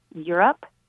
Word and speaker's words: Europe: His name is James Rhys Europe. – Europe, like the continent? – Yes, that's his name.